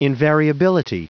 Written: invariability

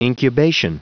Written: incubation

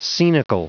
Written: scenical

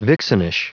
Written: vixenish

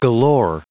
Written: galore

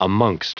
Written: amongst

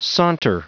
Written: saunter

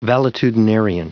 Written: valetudinarian

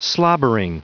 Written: slobbering

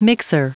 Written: mixer

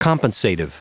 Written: compensative

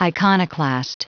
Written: iconoclast

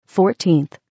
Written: 14th

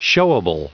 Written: showable

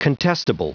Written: contestable

